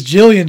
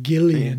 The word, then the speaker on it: Gillian: I thought it was Jillian Jacobs, but apparently she pronounces it Gillian.